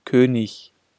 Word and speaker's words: König: IG-Regel. Wenn I und G in einer Silbe hintereinander stehen, wird G als vorderes ch gesprochen. König, 20, billig.